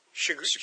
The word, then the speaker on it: chagrin: To my great Sergine. – Chagrin? – How do you say that? Was that how you spell Chagrin? Oh, sorry. To my great chagrin, I opened the book and I couldn't believe my eyes.